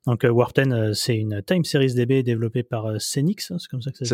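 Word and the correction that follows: SenX: Ça se dit Sense.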